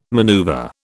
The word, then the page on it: maneuver: British English